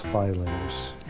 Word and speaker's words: Filene's: The family name is Filene. However, the store's name is pronounced Filene's. – So there we hear Mike Dukakis saying that it's an affect when people call it Filene's.